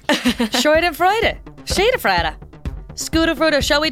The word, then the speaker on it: Schadenfreude: It's Schadenfreude. How do you say that? – Schadenfreude.